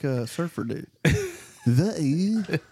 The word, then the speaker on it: Hawaii: In the Hawaiian language, W is pronounced with a V. – Hawaii. Like that? – Hawaii.